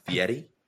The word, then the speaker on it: Fieri: And I think it's pronounced Fieri.